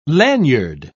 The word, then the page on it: laniard: British English